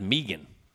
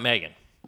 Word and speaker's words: Megan: But, and I think her name is pronounced Megan, believe it or not. Not Megan. I think they do a weird kind of Canadian. – Well, it is Canadian. – Some sort of Saskatchewan, some sort of Vancouver pronunciation.